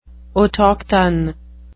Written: autochthon

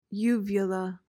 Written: uvula

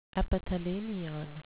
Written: epithalamion

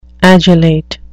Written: adulate

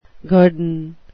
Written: guerdon

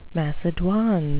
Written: macedoine

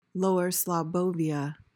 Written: lower slobbovia

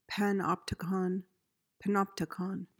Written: panopticon

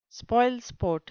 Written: spoilsport

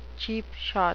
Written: cheap shot

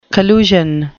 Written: collusion

